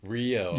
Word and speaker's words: Rio: His accent's like Curitiba, but I would say Rio de Janeiro. – Hats off for pronouncing it correctly. Rio.